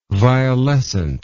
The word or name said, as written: violescent